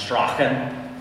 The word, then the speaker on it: Strachan: In Scotland, it would often be pronounced Strachan, which would be more manly, let's just be honest, okay? But it is the Gaelic pronunciation, okay? So the Gaels, the ancient clan of Scotland, they drop out those letters. – Yeah. – And it's like Strand, as in man or clan.